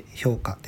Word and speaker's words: 氷菓: 氷菓子と書いて評価ですね